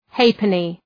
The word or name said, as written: halfpenny